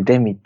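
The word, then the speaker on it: 亜人: アジンって書いてデミって読むんだけどね、くくりとしては日常系アニメのくくりなのかな。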